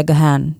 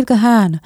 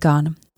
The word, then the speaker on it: Gahan: Já sama jsem měla za to, že se vyslovuje Gahan. Vždycky jsem říkala Dave Gahan, ale není to tak. On sám se říká Dave Gahan. Nepopírá to nějak pravidla fonetiky, jen si majitel jména zvolil tuto možnost výslovnosti.